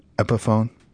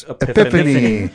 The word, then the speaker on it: Epiphone: No, I've had, like, for me, you know, the guitar Epiphone? – Yeah. – I thought it was Epiphany.